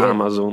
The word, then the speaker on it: Amazon: No, perché anch'io pensavo fosse tipo da Amazing, ma non è da lì, è da Amazon che è come il Rio delle Amazzoni. Quindi se non sbaglio l'accento è eh su sì, eh, Amazon.